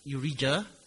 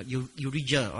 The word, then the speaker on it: Urijah: Yeah, in English you can pronounce it as Urijah. That's one way. Do you pronounce it this way? I forgot. Yeah, Urijah, all right. Otherwise, you can pronounce as Uriah, right?